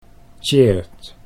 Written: Tjeerd

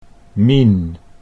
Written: Mien

